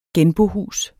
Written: genbohus

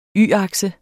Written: y-akse

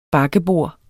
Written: bakkebord

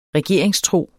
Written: regeringstro

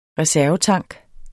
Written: reservetank

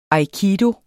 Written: aikido

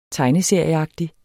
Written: tegneserieagtig

